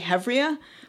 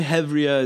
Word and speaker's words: Hevria: One thing I'm working on pretty seriously that is writing, but it's not really, it's funny because my impulse is always to say Hivria, but I think the correct, what is Hivria? I mean, should I be saying Hivria? – Maybe Hivria is the Ashkenaz pronunciation.